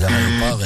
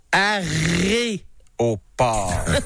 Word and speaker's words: aéroport: Et ces aéroports-là, ce que le souhait de la ville, c'est que les aéroports élèvent des villes. L'autre partie appartient aux aéroports comme tel. Un aéroport. – Vous... Alors, ça s'écrit comme ça se prononce. A-E-R-O-P. Port et non arrêt au port.